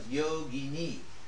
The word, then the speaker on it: yogini: So you add the I, and it becomes yogini.